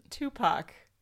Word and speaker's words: Tupac: Tupac.